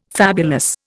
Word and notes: fabulous: Indian

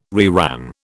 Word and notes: reran: British English

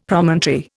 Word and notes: Promontory: Indian

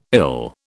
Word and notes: ill: British English

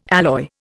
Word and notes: alloy: Indian

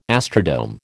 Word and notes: astrodome: American English

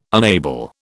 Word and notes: unable: British English

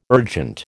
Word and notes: urgent: American English

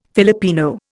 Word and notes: Filipino: Indian

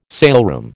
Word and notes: saleroom: American English

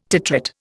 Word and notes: titrate: Indian

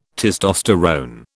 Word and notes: testosterone: British English

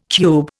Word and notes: cube: Indian

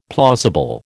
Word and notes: plausible: American English

